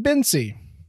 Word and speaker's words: Bence: And number two, you pronounce his name Bensy. Both of those took me completely by surprise. Number one, B-E-N-C-E. One would think that would be Bence. It's apparently Bensy.